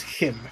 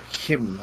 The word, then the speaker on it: CHIM: Chim, is it Kim is how it's pronounced? – Yeah, it's pronounced Him. – Kim, okay.